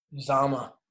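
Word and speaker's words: Uzomah: Uzama.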